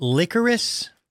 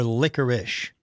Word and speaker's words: licorice: Is it licorice or licorice? Because the way it's spelled, it seems like it's licorice. – I go with the ish. – Ish is how I've always heard it, but it's spelled like is.